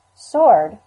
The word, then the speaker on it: sword: Sword, with its silent W, is the most surprising pronunciation of those words.